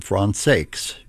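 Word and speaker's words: Françaix: WRCJ in Detroit, in the music of Jean Francais, or John Francaikes, if you want to pronounce it that way. I understand that the family pronounced that final X in his name.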